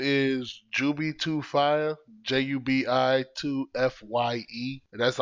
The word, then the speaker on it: Jubi2Fye: His name is Jubi2Fye.